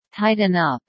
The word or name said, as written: tighten up